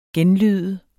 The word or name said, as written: genlyde